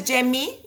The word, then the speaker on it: Yemi: Mi nombre es Jemi y se escribe Y-E-M-I, también me encuentran así si buscan en el buscador.